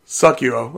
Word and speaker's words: Sekiro: Sekiro.